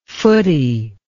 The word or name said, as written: footie